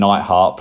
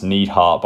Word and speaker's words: Neidhart: Jim Neidhart, pronounced Neidhart by the commentators. That's an ongoing theme with mispronunciations.